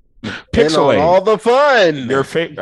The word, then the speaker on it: PixelHate: Welcome to PixelHate, where the H is silent, but the hate is very, very real.